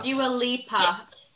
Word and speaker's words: Dua Lipa: how you do say her name, Dua Limpa. – Dua Lipa.